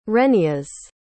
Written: rheneas

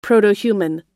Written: protohuman

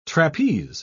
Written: trapeze

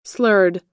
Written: slurred

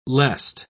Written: lest

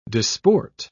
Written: disport

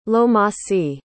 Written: lomasi

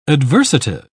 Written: adversative

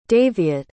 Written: daviot

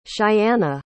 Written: cheyanna

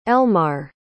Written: Elmar

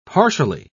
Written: partially

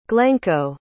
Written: glencoe

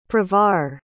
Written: pravar

